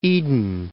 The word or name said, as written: Eden Prairie